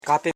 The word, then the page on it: kape: ka.pe; Koro